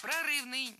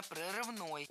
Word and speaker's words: прорывной: Прорывный, неверно, правильно, прорывной. Ударение в этом прилагательном падает на окончание. Прорывной.